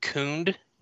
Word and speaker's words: Khund: So he is most people pronounce it Khund. Okay. K-H-U-N-D. – Yes, K-H-U-N-D, yes.